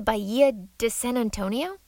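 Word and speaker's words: Bahía de San Antonio: Hey, do you live close to the Bahia de San Antonio? Am I saying that right? – Yes, I am indeed in the Bahia de San Antonio.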